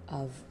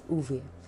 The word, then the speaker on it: Ove: Today, I'll be reviewing A Man Called Av by Fredrick Backman. So when I read the book, the pronunciation in my head was Av, but later on I found out that the Swedish pronounce it as Uve.